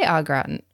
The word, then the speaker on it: au gratin: I'm going to try au gratin.